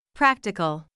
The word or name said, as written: practical